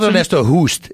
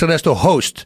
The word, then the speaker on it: Ernesto Hoost: Not Ernesto Hoost. It's Ernesto Host.